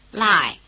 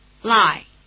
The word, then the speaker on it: Lai: Lai, Lai, Lai. – Notice that the diphthong I is spelled A-I as in Shanghai, which is pronounced in Chinese like this.